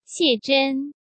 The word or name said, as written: Xie Zhen